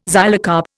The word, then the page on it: xylocarp: Indian